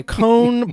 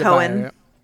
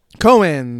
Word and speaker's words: Cohn: It's Cohen. – Cohen. There's no E, but I do recall now that you mentioned it that it's pronounced Cohen in the film, but I like Cohn better.